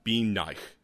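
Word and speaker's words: bean nighe: It's spelled Bean, B-E-A-N. – Yeah. – And then Nye, as in like N-I-G-H-E. – Oh, yeah. – Bean Nye, or something like that.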